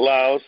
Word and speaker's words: Laos: So tonight we're going to be talking about Laos or Laos, mm-hmm. whichever way you pronounce it.